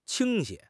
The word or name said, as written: 倾斜